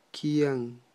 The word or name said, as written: kyan